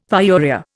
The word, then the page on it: pyuria: Indian